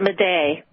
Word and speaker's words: Madej: Tell me what your discussion with Dr. Carrie Madej, M-A-D-E-J. – She's so brilliant. She pronounces it Madej.